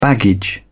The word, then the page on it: baggage: British English